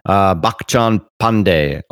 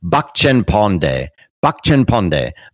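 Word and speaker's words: Bachchhan Paandey: This is Bakchan Pandey. Oh, sorry about that. Bakchan Pandey. I looked up to see how they were pronouncing it. I think that's a little better.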